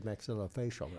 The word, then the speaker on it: maxillofacial: Did you say maxillofacial right? – Maxillofacial. – Maxillofacial. Do that again. – You're two for two. – Okay, maxillofacial.